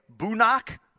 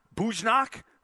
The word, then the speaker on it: Bujnock: He's supposed to be out there along with, oh, I got to double check a pronunciation on this, from Hutto, Brody Bujnok, B-U-J-N-O-C-K, Bujnok, Bujnok.